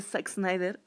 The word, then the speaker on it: Zack Snyder: con esta versión de Zack Snyder fue de Zack, Snyder. yo dije, lo pronuncié bien.